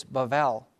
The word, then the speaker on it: Babel: What's very interesting is the tower that they build, Babel, in Hebrew, when you read it, it's Babel. There's a B, but the Hebrews pronounce it with a V. Unless there's a certain mark in there, then you go B.